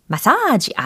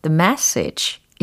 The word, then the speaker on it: message: The message. 마사지 아니고 the message. 이렇게 발음을 하셔야 되겠죠.